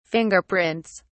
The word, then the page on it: fingerprints: British English